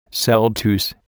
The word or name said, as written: celtuce